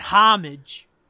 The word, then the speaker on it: homage: We call that homage. – I think the H is silent, though.